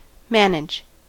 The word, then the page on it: manage: American English